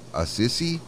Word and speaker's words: Assisi: Saint Francis of Assisi. That's how you would pronounce it.